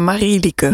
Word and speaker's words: Marilieke: Zeg ik het goed, maar Ja, het is een vreselijke naam, Marilieke.